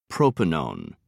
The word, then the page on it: propanone: British English